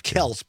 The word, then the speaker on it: Kelce: Right, yes, Travis Kelce. – I got confused for a second because I was thinking it looks like Kelce, but he pronounces it Kelce.